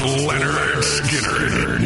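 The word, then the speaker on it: Lynyrd Skynyrd: It's pronounced Leonard Skinner with Hairball tonight.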